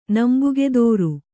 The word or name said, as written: ನಂಬುಗೆದೋರು